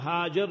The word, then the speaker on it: Hajar: First was Nabi Ismail alayhi salam, his honorable mother, Sayyida Hajar, Hajara radiallahu anha, both ways you pronounce it.